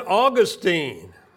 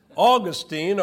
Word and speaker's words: Augustine: So Origen, but then Augustine. I was checking online. That's pronounced properly in two different ways. Augustine or Augustine.